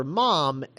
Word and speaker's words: ma'am: Oh, she's great, and there's this great bit when she first comes on the ship, and that guy calls her mom, and I'm just like, oh, yeah, because he's British, and that's how they pronounce ma'am. Mm-hmm. They pronounce it mom.